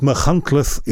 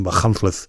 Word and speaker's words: Machynlleth: at Machynlleth in Wales. And, you know, I only put this story in so I could say Machynlleth, because it took me a long time to learn how to pronounce it.